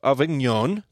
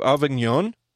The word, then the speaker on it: Avignon: The papacy of Avignon, did I say that correctly, Brent? – I think so. – All right. The papacy of Avignon